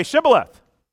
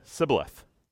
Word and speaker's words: shibboleth: And when he said no, they said to him, then say Shibboleth. And he said Sibboleth, for he could not pronounce it right.